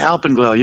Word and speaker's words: Alpenglow: Is it Alpenglow? – Yeah, Alpenglow. You got it.